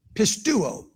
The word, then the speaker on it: pisteuo: The word for believe is the verb pisteuo. Pistis is a noun. Pisteuo is a verb. P I S T E U O. Pisteuo.